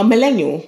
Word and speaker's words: millennial: It's millennial, right? – We're finding out together. – Am I saying it properly? – No, millennial. – Millennial. – There's more L's than millennial.